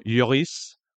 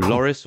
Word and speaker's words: Lloris: Just very quickly, we'll go to Matt on this one, because I know he's a good mate of Hugo Lloris, or Loris, or Lloris, depending on how you want to pronounce it, depending on where you are from in France.